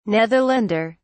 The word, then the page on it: netherlander: British English